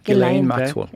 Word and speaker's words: Ghislaine Maxwell: Er det sånn du uttaler? – Ghislaine Maxwell. – Ja.